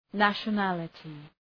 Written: nationality

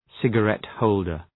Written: cigarette-holder

